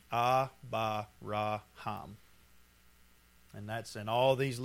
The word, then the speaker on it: Abraham: So when you pronounce this, it's Abba-ra-ham.